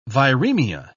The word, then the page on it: viremia: British English